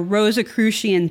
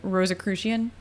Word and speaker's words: Rosicrucian: At the time, both Yale and New Haven were hubs of progressive, Freemasonic, or Rosicrucian thinking and activity, and it's thought that many of Sarah's relatives themselves were Freemasons.